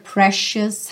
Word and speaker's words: precious: I love your precious heart. Olha que interessante essa palavra precious, essa letra C vai ser pronunciada como um X.